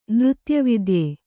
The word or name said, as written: ನೃತ್ಯವಿದ್ಯೆ